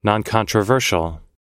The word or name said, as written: noncontroversial